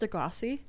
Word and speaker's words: Degrassi: He was on Degrassi and they're like... – It's pronounced Degrassi. – I don't know. – I thought it was pronounced... – Sounds like the same thing.